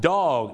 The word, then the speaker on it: dog: dog ต้องออกเสียงดีๆนะครับ dog นะครับไม่ใช่ dog นะฮะถ้าออกเสียงว่า dog เนี่ยนะฮะจะแปลว่าเอาเรือนะครับไปเทียบท่าไปจอดเรือนั่นเองครับ dock the boat นะฮะต้องออกเสียงว่า dog